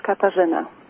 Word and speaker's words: Katarzyna: Yes, Katarzyna.